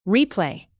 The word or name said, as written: replay